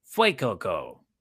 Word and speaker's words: Fuecoco: How you say it? – Fuecoco. – It's like fire, like fuego.